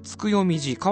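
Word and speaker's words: 月読寺: つくよみじ、変わった名前のお寺なんですけどね、月読む寺と書いてつくよみじ、これが特定のね宗派に属しているわけではなくて、駆け込み寺的なものを志して作ったお寺らしいんですけれども、詳しくはですね、つくよみじのサイトを見てみてください。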